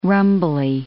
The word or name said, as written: rumbly